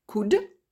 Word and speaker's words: could: La branche symétrique de can, c'est could, C-O-U-L-D. La branche symétrique de will, c'est would, W-O-U-L-D. Je rappelle encore, le L ne se prononce jamais. Et le symétrique de shall, c'est should, S-H-O-U-L-D. Le symétrique de may, c'est might, M-I-G-H-T.